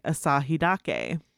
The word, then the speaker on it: Asahidake: In the pronunciation portion of anything that I have looked up on this, they are recommending I say Asahi Dake.